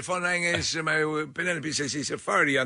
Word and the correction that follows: Farhadi: on the phone. I think I'd have to go. – Farhadi. – Oh. – I think is how you pronounce it.